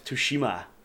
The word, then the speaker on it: Tsushima: There's so much to talk about with the Ghost of Tsushima. – Oh my god! Please say that again. – Ghost of Tsushima.